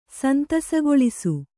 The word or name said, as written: ಸಂತಸಗೊಳಿಸು